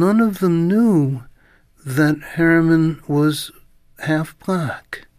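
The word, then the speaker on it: Herriman: Also, you know, there was just a brilliant biography of George, how do you say Harriman? – The cartoonist who did Crazy Cat.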